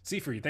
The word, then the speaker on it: Seyfried: Seyfried.